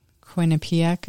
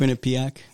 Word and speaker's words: Quinnipiac: Right there, Quinnipiac, I think. Quinnipiac.